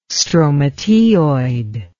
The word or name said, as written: stromateoid